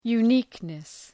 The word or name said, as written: uniqueness